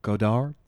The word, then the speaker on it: Godard: Yes, cinema uh, by two of my favorite auteurs, uh, French Stewart and Jean Luc Godard. Yes, am I saying that right?